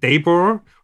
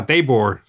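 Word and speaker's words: Daboll: Ich habe übrigens mit Sebastian Vollmer gesprochen, dessen Trainer war Brian und der den Namen Daybor ausgesprochen, was ich sensationell fand. Hab ich ihn nochmal nachgefragt, Sebastian, meinst du das echt? Der heißt Brian Daybor? Der meinte, ja, Daybor. So haben wir ihn genannt, so hat er sich selbst genannt.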